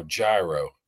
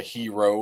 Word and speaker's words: gyro: No, gyro. Yeah. Sometimes they have it at the Steelers cafeteria and they walk through and say, I'll take a hero.